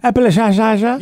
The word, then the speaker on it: Appalachia: Is that how you say it? Appalachia? – Appalachia. – Yeah, exactly. West Virginia.